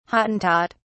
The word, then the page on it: hottentot: British English